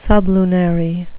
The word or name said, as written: sublunary